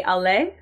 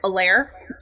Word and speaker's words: aller: I mean, I did when I went to high school, I could at least say allez. Like, you know, it's spelled A-L-L-E-R, and I understood that E-R was A, versus other kids in my class that were like Allaire.